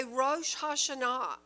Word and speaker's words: Rosh Hashanah: you're going to see it probably will say Rosh Hashanah or Rosh Hashanah, one of the other. It's pronounced both ways.